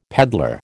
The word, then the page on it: peddler: American English